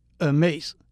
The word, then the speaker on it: Hermès: 大家都听过的名牌爱马仕，法文是 Hermès，第一个字母 H 是不发音的。